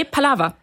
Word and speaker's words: Palaver: Und zu diesem sogenannten Citizen Science Projekt Palaver liegen ja jetzt auch, ich betone es richtig, ne, auf der letzten Silbe? – Nee, Palaver tatsächlich.